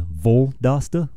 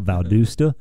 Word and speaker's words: Valdosta: I've heard Voldosta, like VL instead of VAL, Valdosta.